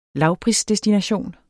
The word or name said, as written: lavprisdestination